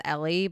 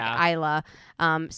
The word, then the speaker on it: Ayla: So we start with, I believe it's Ayla, I think Ayla is how they pronounce her name. – Yeah, when I read it, I thought it was Ellie, but it's clearly not. It's like Ayla.